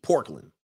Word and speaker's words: Portland: You're hearing pork? Portland. Hold on for a second. Let me spell it. P-O-R-T-L-A-N-D. Portland. It's like Oakland.